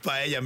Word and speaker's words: paella: So the thing is, like, paella is paella. Actually the meaning of paella means for her, para ella. So the word pronounced paella.